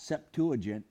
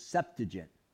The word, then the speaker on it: Septuagint: That's really how you pronounce that. It's not Septuagint, it's Septuagint.